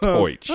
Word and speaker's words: hoich: Yeah, hoich is a combination of ho and bitch, but pronounced hoich.